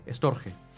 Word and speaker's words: Storge: después el cuarto tipo de amor, que es el que ya habría nombrado Enrique, es el Storge se dice. Este reconozco que no sé cómo se pronuncia este. – Es S-T-O-R-G, Storge.